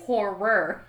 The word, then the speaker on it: horror: Horror. When we're talking about Tinder, we really need to pronunciate the second R.